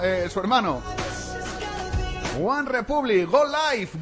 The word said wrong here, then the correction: Good Life: Good Life. Que luego me dice eh, Zara que pronuncio mal. Good Life.